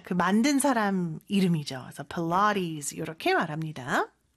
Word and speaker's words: Pilates: 필라테스인데 발음이 참 독특하죠. 그렇죠? 필라테스라고 하면은 사실 원어민들은 못 알아들을 수 있을 것 같아요. – Yeah. – Pilates. 이렇게 발음을 합니다.